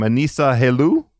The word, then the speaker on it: Anissa Helou: And I'm sorry if I mispronounce her name. Anissa Helou? – Yeah, you've got it.